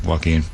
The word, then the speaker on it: Joaquin: Is it Joaquin Davis? Where's our pronunciation? – Joaquin Davis. – Davis. I called him Joe Quinn. I'm sorry, Joaquin.